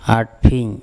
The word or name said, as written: aTpHing